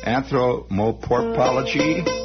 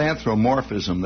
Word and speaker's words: anthropomorphism: Anthropomorphology. It's not anthropomorphism. There's another syllable in there. Anthropomorphism. That's it. Anthropomorphism.